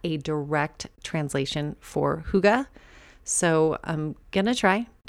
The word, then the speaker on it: hygge: And about this time, I was hearing or seeing the word hygge everywhere. Um, admittedly, when I started seeing it everywhere, I was saying higi. it's spelled H Y G G E.